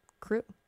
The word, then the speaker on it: CHRU: But before that, UNC Chapel Hill WXYC wants you to know about the Carolina Harm Reduction Union, known as CHRU, pronounced CREW.